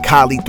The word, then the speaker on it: Kahlee: Follow me, everything at Kahlee 310, K-A-H-L-E-E 310. It's like Kali, like rhymes with Ali on a skateboard, does not rhyme with Cali, and there's no connection except I rep that shit hard.